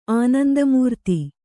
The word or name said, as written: ಆನಂದಮೂರ್ತಿ